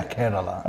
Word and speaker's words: Kerala: Yeah, Kerala.